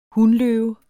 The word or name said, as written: hunløve